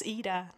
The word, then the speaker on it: Ida: I mean, when I started to know people from all over the place, they started to call me Ida from the beginning, and then I was like, well, I pronounce it as Ida.